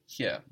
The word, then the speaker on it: hier: And the word for here? It's the same. – Bleiben. – No, the same as English. – Here. – Here. Good. The same as in English, but spelled H-I-E-R.